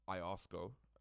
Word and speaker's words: IOSCO: Now, before we get, now I know we have the IOSCO.